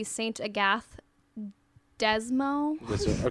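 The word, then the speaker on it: Sainte-Agathe-des-Monts: I guess an English way of saying it would be Saint-Agathe-des-Monts. – Okay. – But it's French. Yeah, it's a French town.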